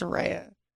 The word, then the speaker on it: Soraya: But yeah, so whenever someone calls me Soraya, I'm like, it's not Soraya. It's Soraya.